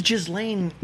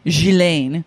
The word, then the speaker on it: Ghislaine: But how is Ghislaine? – Do you know how you actually pronounce her name? Ghislaine. – Oh, that's much better.